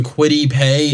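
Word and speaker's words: Kwity Paye: We ended up going Quiddy Pay.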